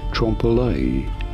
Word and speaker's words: trompe-l'œil: For reference, in French, this is normally said as trompe-l'œil. Trompe-l'œil. It'd be fine in English to say it as trompe-l'œil. Trompe-l'œil from French, trompe-l'œil.